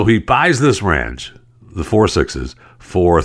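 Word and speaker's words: Four Sixes: So it's a 6666 ranch, and they pronounce it 4 6's.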